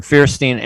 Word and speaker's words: Feierstein: Ambassador Feierstein, and I hope I'm pronouncing your last name correctly.